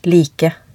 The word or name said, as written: like